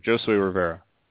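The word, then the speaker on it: Josué: Jay says Jose Rivera. – Josue. That's what I mean. I knew the pronunciation was a little weird. Josue Rivera.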